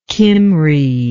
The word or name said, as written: cymry